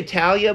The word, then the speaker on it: Thalia: One of the most famous ghost stories associated with the DeSoto Hotel involves a young girl named, I'm going to say Talia, but it's spelled T-H-A-L-I-A. – And it's probably still Talia. – Because, like, I look at it and I'm like, Thalia.